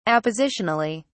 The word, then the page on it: appositionally: British English